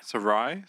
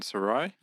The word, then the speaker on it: Sarai: But Abram and how do you pronounce Sarah's? – Sarai. – Abram and Sarai.